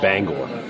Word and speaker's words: Bangor: Bangor, Maine, you know, or Bangor, or however you're supposed to pronounce that. – It's actually pronounced Bangor.